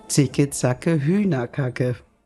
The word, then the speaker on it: Hühnerkacke: Könnten Sie vor dem Hühnerkacke bitte keine Pause machen? – Zicke, Zacke, Hühnerkacke. Und die Betonung ist auf? Hühner.